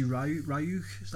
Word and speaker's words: Ryuk: I was enjoying it, you know, when you feel you first see Ryuk, that's how you pronounce it, Willem Dafoe.